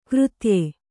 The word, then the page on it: ಕೃತ್ಯ: křtya